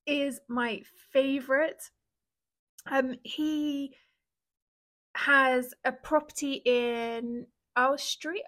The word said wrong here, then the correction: Oswestry: I'm sorry, Mark, I always butcher the name of that town. Oswestry. That's it, right?